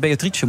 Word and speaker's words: Beatrice: hoe, waarom weet je zo zeker dat het Beatrice moet zijn dan? – Ja, dat is op zijn Italiaans. Zo, zo heb ik het. Mijn vader leerde mij dat, dat ik het zo moest, als fietsje moest uitspreken.